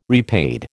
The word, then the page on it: repaid: American English